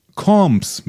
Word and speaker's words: Combs: Birger ähm, in unserem Forum, Jeffrey Combs wird als Combs mit langem O ausgesprochen. Also Combs.